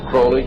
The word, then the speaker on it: Crowley: He likes to have his name pronounced Crowley to rhyme with holy.